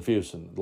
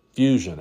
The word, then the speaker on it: Fuson: Fuson is pronounced It's spelled F-U-S-O-N, but it is pronounced F-E-W, like few son. If you wrote it out on paper the way it's actually pronounced, it would be F-E-W-S-O-N.